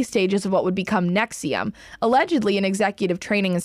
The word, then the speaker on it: NXIVM: I would just like to pause for a second and just tell you that Nexium, by the way, is spelled NXIVM in all capitals.